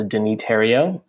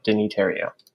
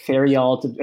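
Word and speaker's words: Denis Theriault: So Deniterio. And if you ever have a hard time pronouncing my last name, it's pronounced like the, you know, Ontario, Terrio, Deniterio.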